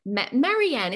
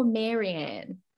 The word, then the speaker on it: Marianne: I'd love everyone to meet Marianne. Is that how you say your name, or Marianne? – Marianne, Gilligan's Island.